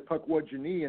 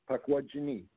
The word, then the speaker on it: Pukwudgeni: Yeah, but it's actually Pukwudgeni in the Algonquin, which is another interesting thing because the last two syllables are genie, Pukwudgeni.